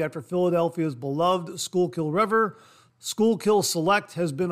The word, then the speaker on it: Schuylkill: While editing this segment, I looked up the pronunciation of this name, and it's Schuylkill. Schuylkill.